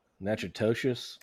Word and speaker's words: Natchitoches: Natchitoches. It's Natchitoches is how it's pronounced.